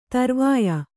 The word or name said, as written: ಧ್ರೌವ್ಯ